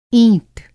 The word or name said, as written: ईंट